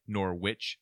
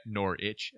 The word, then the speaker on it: Norwich: We had a comment about how we say Norwich, and that we pronounce it like Norwich as if it's like a sandwich, and it should be Norwich as opposed to like Greenwich Village, that type of thing. Like it's an itch.